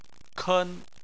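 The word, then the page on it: ken: tone 1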